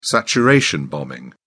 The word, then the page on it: saturation bombing: British English